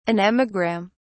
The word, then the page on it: anemogram: British English